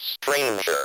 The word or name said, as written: stranger